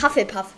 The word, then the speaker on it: Hufflepuff: Nein, es ist Hufflepuff.